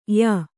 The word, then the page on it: ಯ: ya